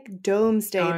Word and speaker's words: Domesday: It's spelled like D-O-M-E-S. – Yeah, it looks like Domesday, but I always said Doomsday in my head too, so I wasn't sure.